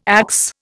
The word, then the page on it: axe: Indian